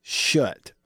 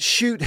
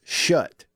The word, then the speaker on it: Schutt: Gage says, I used to sell athletic equipment and it is pronounced shut. Okay. It's not shoot helmets. Officially from someone who sold them. Gage on the Country Pleasing text line has put it to rest. It's shut. S-C-H-U-T-T, shut helmets.